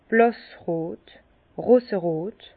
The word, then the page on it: blàssrot, roserot: Bas-Rhin; Herrlisheim